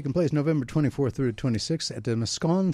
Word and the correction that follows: Moscone: Center in San Francisco, California. Moscone. What? – Moscone. – Your mic's off. Oh, sorry. Moscone. – Moscone. Okay. I mispronounced that one.